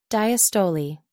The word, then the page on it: diastole: American English